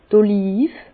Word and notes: d' Olive: Bas Rhin; Herrlisheim

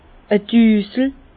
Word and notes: e Düsel: Bas-Rhin; Herrlisheim

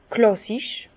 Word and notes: klàssisch: Bas-Rhin; Herrlisheim

